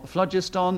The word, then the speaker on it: phlogiston: No, when I was at school, I was told it was phlogiston, and it was P-H-L-O-G-I-S-T-O-N. – Everyone knows you pronounce it as in flog. Phlogiston.